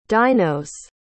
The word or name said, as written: dinos